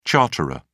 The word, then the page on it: charterer: British English